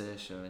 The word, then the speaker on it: Saoirse: I think it's Saoirse, if it's Irish.